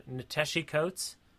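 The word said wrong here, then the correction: Ta-Nehisi Coates: I'm going to say it wrong. – Nateshi Coates? – Do you know Nateshi Coates? I'm pretty sure I'm saying it wrong. – Give me a second. – Tanashi Coates, not. – Okay. – I took the ta and stuck it in the middle of his first name, like an asshole.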